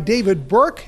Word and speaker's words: David Burke: Well, we're here at Double Barrel Steak by David Burke, and I said it correctly.